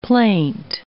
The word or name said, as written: plaint